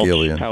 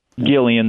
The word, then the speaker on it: Gillian: I don't know how to say her name. – Yeah, Gillian Welch. – Gillian. – Gillian. There we go.